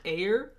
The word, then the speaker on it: Ayer: It'll be called the Ayer cut. – I didn't have confidence in pronouncing it as Ayer. – Ayer. – Ayer. – Yeah. – I feel like I'm just saying like air, like the element.